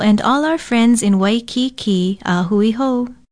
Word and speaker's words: Waikiki: Waikiki is spelled W-A-I-K-I-K-I with kaha ko over the last two vowels. Pronounce it waikiki.